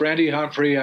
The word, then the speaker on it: Randy Humphrey: So it's Randy Humphrey.